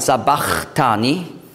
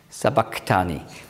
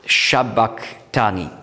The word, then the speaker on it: Sabachthani: Darum Sa, und viele sagen Sabachthani, aber das Chi, das griechische Chi, hat man früher ausgesprochen als K, Sabak, Sabachthani. Und auf Aramäisch ist es ein Q. Also Shabachthani.